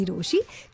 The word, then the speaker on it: Rochie: नाम है मिर्ची रोशी, काम है आपसे प्यार करना, नाम की स्पेलिंग है ROCHIE.